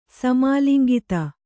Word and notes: ಸಮಾಲಿಂಗಿತ: samālingita

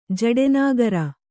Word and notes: ಜಡೆನಾಗರ: kannada

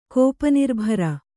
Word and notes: ಕೋಪನಿರ್ಭರ: kannada

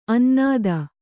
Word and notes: ಅನ್ನದ: annada

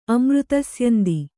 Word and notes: ಅಮೃತಸ್ಯಂದಿ: Kannada

